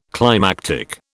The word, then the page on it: climactic: British English